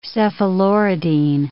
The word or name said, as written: cephaloridine